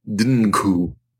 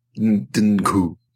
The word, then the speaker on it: Dnghu: Does it ever tell you how to pronounce this name? D-N-G-H-U. – Yes, it's supposed to be a syllabic N, so it should be Dungu. Dungu.